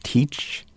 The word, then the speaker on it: TEACCH: There was another program that I was interested in called Teach TEACCH, if I'm pronouncing that correctly.